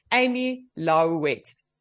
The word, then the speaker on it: Amy Lalouette: Amy Lalouette.